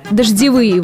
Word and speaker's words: дождевые: Например, дождевые воды размыли грунтовые дороги. Что характерно, и в слове «дождевые», и в слове «грунтовые» ударение на предпоследний слог.